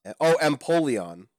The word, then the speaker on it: Empoleon: Wow, I've been saying it wrong this whole time. – Fire Eevee is Flareon, and don't worry, we'll talk about Eevees later. Hold that thought. – Empoleon. – Empoleon. – Think of it like Napoleon, except a penguin.